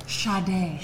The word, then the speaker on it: Sade: That's the, and it's spelled S-A-D-E. – Yeah, not Sade. – Yeah. – Sade. – Sade.